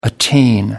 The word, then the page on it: attain: American English